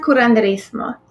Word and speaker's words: curanderismo: You mentioned earlier that your medicine is not Eastern or Western, but earth medicine, and it's called curanderismo. Is that, am I saying that correctly? – Well, it's pronounced curanderismo, yes.